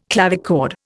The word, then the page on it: clavichord: Indian